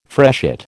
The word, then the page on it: freshet: American English